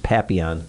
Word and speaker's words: Papillon: Oh, and it's not. It's called Papillon, right? – Papillon. Is it Papillon? Yeah. There's like an L in there. Like, did you tell me the L is silent? – It's called, it's, are there two L's? – I believe it is pronounced Papillon. – Papillon. – Are there two L's next to each other? – I thought so, yeah. – Well, two L's make a Y.